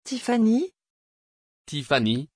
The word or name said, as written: Tiffany